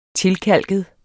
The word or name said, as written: tilkalket